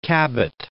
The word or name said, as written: Cabot